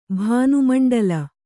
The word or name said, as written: ಭಾನುಮಂಡಲ